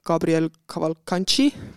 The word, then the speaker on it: Gabriel Cavalcanti: Äh, plaan oli intervjueerida siis äh, nende vastliitunud Gabriel Cavalcanti, kui ma nüüd selle nime õigesti hääldasin. oli õige.